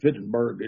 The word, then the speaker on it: Wittenberg: And so Luther nailed a list of 95 theses for debate regarding these indulgences to the church door in Wittenberg, or Wittenberg, it would be the German pronunciation, I guess, on October the 31st, 1517.